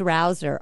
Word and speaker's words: Rouser: So spelling it is M-E-I-S-H-A. And the last name's Rouser, R-O-U-S-E-R.